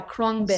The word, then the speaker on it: Khruangbin: Oh, yeah, Khruangbin.